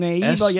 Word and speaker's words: Nieva: Soy Nieva? – Mm-hmm. Naiva, yes. Close enough, Adam. – What is it? – Go ahead. Just go ahead and. – How do you say it? – I think it says Naiva.